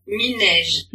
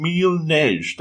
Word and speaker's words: Mille-neige: Mille-neige. – You hear that? Mille-neige. Okay, so even if I say it wrong, Mille-neige. That's how you say it.